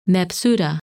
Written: Mebsuta

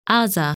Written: Azha

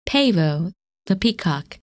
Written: Pavo

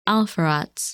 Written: Alpheratz

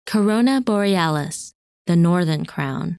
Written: Corona Borealis

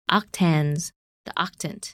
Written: Octans

